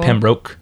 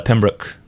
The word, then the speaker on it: Pembroke: I think I probably said it more like Pembroke, but it's Pembroke, you know? So but he said Pembroke, correctly pronounced.